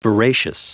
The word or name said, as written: veracious